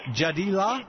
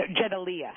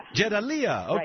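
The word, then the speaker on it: Jadaliyya: She's also co-editor of the new news journal How do you say this? Jadila? – No, Jedalia. – Jedalia, okay.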